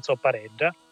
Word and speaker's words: zoppareggia: Allora, l'hai pronunciata benissimo, perché si pronuncia zoppareggia ed è la danza più antica che siamo riusciti a documentare, la danza associata alla zampogna.